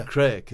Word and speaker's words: craic: No, because it's spelt C-R-A-C-K. – Yeah. – Crake is spelt C-R-A-I-C, crake.